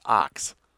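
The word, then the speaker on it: Ochs: Lewis Marshall contacted Adolph Ox, pronounced Ox.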